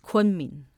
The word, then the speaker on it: Kunming: Kuaming, it's called? – Kunming. – Kunming. – Yes. – Okay. – Perfect pronunciation. Kunming is the capital.